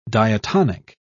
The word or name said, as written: diatonic